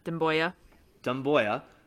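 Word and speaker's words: Doumbouya: But in other Pistons news, the Nets traded Sekou Doimbou. I can't even pronounce his name. – Dumboya, to the Rockets for a second round pick, and then the Rockets proceeded to cut Sekou.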